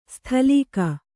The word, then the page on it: ಸ್ಥಲೀಕ: sthalīka